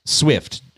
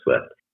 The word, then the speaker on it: SWFT: But yeah, Swift, so SWFT without an I in there. But you do pronounce it Swift, otherwise as if it did, correct? – Yeah, exactly. Okay. Similar to Lyft, but Swift.